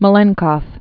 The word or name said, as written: Malenkov